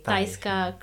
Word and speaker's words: Tajska: Okay, it's spelled as T-A-J. – Tajska.